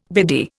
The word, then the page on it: biddy: Indian